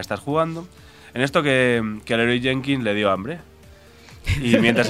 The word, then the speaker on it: Leroy Jenkins: Porque se dice así, ¡Leroy Jenkins!